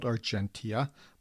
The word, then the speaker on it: Argentia: Yes, that's the correct pronunciation. It's spelled Argentia, but it's pronounced Argentia, as I have been informed in no uncertain terms by certain Newfoundlanders who will not be toyed with.